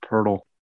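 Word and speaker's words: Pöltl: Just say Jacob Puddle like a million times, and we're good. – It's pronounced Purtle. – I can't believe that. There's no R's in it.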